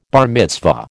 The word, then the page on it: bar mitzvah: American English